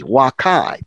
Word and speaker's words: vaccae: So wakai, V-A-C-C-A-E, wakai, pronounced I, even though it's A-E, wakai.